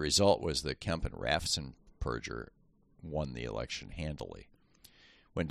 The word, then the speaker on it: Raffensperger: He won by 50,000 votes, and then repeated his efforts again in 2022 through his Secretary of State, Brad Raffensperger, whose name, ironically, is correctly pronounced Raffensperger.